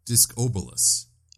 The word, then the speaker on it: Discobolus: By the way, the statue is apparently named Discolibus. – Discolibus? Huh. – Or Disc... I'm sorry, I mispronounced that. Discobolus.